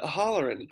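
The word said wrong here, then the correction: Hallorann: correctly. Oh, I started saying Oh Hollerman and like all these things. So I'm sorry to all the O'Holloran oh, fans out there in the world. I'm saying it correctly now, correct? – Halloran, Halloran. I.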